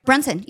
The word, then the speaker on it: Brunson: Like Jalen Brunson am I saying his name right? – Brunson, yeah.